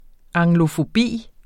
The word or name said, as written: anglofobi